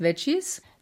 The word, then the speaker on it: Veggiezz: Das schreibt sich ganz lustig mit V-E-G-G-I-E-Z-Z. Aber spricht man wahrscheinlich aus als Veggies.